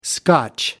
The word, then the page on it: scotch: American English